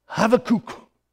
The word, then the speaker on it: Habakkuk: I grew up learning it to pronounce it Habakkuk. Some of us are saying it's Habakkuk. Well, I listened to it in Hebrew. And here's what it says, and I spelled it out phonetically. Habakkuk.